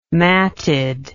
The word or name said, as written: matted